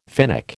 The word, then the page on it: Finnic: American English